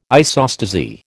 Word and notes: isostasy: American English

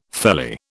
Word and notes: felly: British English

